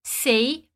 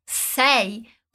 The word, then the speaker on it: sei: Atenção com a pronúncia de sei. A gente não diz sei, mas sei.